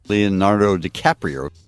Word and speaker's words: Leonardo DiCaprio: Leonardo DiCaprio.